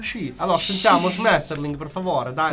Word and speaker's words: Schmetterling: Reaper Schmetterling.